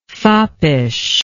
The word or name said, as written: foppishness